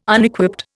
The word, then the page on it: Unequipped: Indian